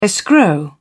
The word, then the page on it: escrow: British English